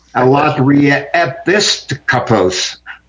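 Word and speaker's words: Alatria episkopos (ἀλλοτριεπίσκοπος): Alatria episkopos. The emphasis is on the IS. Alatria episkopos.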